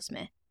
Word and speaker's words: Smythe: Julia Smith, is it Smith? It's spelled Smythe. Is that pronounced Smythe? – Yeah, I think it's still Smith.